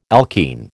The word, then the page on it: alkene: American English